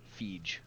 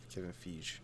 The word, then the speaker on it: Feige: According to my Google search that I did like 3 days ago, it's pronounced F-E-E-J. Feige. – Kevin Feige.